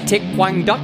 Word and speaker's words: Thich Quang Duc: This amateur saw that famous photo of the Vietnamese Buddhist monk, I believe it's pronounced Thich Quang Duc,